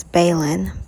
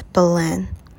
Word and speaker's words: Belen: A lot of people missay my name as Balin, but it's Belen.